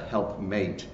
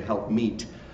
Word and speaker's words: helpmeet: So some people mispronounce it a helpmate. It's actually a helpmeet, the old expression, a helper suitable.